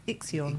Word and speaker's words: Ixion: Ixion, how do I say that? I-X-I-O-N. Ixion. – Everybody says that. – Is it Ixion? – Everybody says that. Every time they read that out, Ixion. – Yeah, it is Ixion.